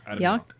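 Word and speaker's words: Yauch: Diamond, Adam MCA Yock? How would you say it? – Yock. Yeah.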